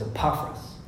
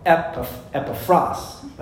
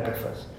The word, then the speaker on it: Epaphras: The correct pronunciation is Epaphras. No, I said that wrong. Epaphras. That's the correct pronunciation.